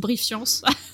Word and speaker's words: Brief.Science: C'est comme ça qu'on doit le dire, ou est-ce qu'on dit juste Brief Science? – Brief Science. – Brief Science, mais qui s'écrit Brief.Science.